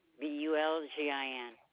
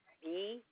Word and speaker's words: Bulgin: Michelle Bulgin, Bulgin. – Can you spell your first name? – Michelle, Michelle. – Michelle? – Yes. – And your last name, can you spell it? – Bulgin.